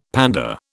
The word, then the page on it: panda: British English